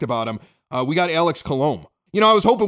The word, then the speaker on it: Colom: Well, it's Colom. I had to look it up on Baseball Reference. They have a little pronunciation guide there, which I point Gene Honda to every once in a while during the regular season.